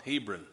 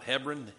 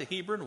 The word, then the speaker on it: Hebron: Hebron. That's how I call it. Hebron. Some call it Hebron. Hebron. Hebron. H-E-B-R-O-N. That's where it was. Do you remember how he got to Hebron?